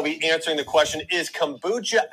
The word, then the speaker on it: kombucha: Kombucha? Kombat? How do you say it? I know you're probably screaming it. It's kombucha, bitch.